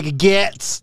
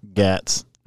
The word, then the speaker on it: Gaetz: You got Matt Gaetz or Gates. I literally say that every time. Can we settle officially on how you say it? – Gaetz? Gaetz. – Is it Gates? – Mm-hmm. No, it's Gaetz.